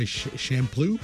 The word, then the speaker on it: Champloo: How do you say it? – Yeah, you got it right. – Samurai Champloo, sure.